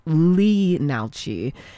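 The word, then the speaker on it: Leenalchi: Inalchi, the L is silent, so it looks like it's Lee Nalchi.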